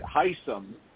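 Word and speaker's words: Hisam: Hysum is actually how he pronounces it. Hysum is 45.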